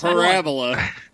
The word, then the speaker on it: Parabola: It's parabola. You put the wrong emphasis on the wrong syllable.